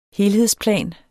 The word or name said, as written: helhedsplan